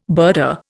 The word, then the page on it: birder: Indian